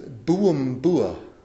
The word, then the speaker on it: Buabua: In Fiji, the Fiji Bible College is right off this thing called, this road that's spelled B-U-A-B-U-A. All together, one word. And I looked into the map and I said, oh, that's Bua Bua. This is no. It's Buumbua.